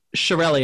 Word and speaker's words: Chiarelli: Chiarelli, and it's spelled C-H-I-A-R-E-L-L-I.